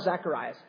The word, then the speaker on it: Zacharias: Because when the angel tells Zacharias, or that's the Greek way of pronouncing his name, Zachariah is the Hebrew name way, so I'll probably toggle back and forth, you know.